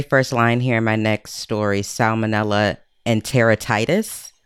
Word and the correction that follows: Enteritidis: Is that how you say Barbara. – That? – Oh, this is my favorite. So I told Stacy the other day, I have switched from my favorite microbial pronunciation of monocytogenes to enteritidis. – Oh, is that what it is? – That's what it is.